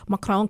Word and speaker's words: Macron: I mean, I look at Le Pen and I look at Macron, and I'm probably not pronouncing it right. Macron, I'm trying Macron.